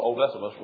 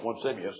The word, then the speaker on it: Onesimus: He called Onesimus one Simeus, and I got tickled.